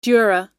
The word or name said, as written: dura